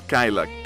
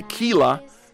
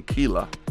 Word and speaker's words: Kyla: Siempre se me olvida decir la pronunciación es Kyla. K-Y-L-A. Porque Kyla sonaría como killer. Como dicen otras personas, lo pronuncian Kyla. No, este es Kyla.